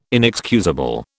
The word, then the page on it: inexcusable: British English